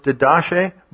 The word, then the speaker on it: Didache: It looks like it should be pronounced Didache, but it's Didache.